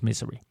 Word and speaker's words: Missouri: i Missouri. Udtales Missouri.